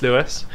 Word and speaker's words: Louis: It's spelled the funny French way, like the king. So Louis. Ha. But it's still pronounced Louis.